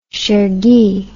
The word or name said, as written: chergui